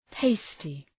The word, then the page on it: tasty: ‘teıstı